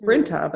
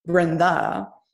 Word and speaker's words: Brynta: I'm like, it's Brynta, but in our culture, it's actually Brynda.